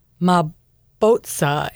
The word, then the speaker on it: Mabotsa: It was called Mabotsa, M-A-B-O-T-S-A, Mabotsa. And when he was there, my African pronunciation is not very good.